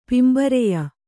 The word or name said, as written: ಪಿಂಬರೆಯ